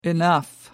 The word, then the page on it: enough: American English